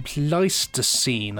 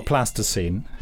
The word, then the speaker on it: Pleistocene: Is it Pleistocene? – Pleistocene, I believe so. – Not Plasticine. – Not Plasticine. Don't get it confused with Plasticine.